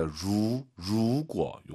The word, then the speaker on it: 如果: 루 아니에요. R 발음 더 말해야 됩니다. 루구어 요거 기억하시고요.